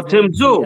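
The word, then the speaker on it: Tim Tszyu: Oh, Tim Zhu.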